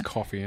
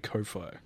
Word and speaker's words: Ko-fi: Looks like it's coffee and you go, "Oh, coffee." But it's actually Ko-fi. – Well, I'm going to pronounce it like Ko-fi because it's spelt like Wi-Fi, so that's exactly it.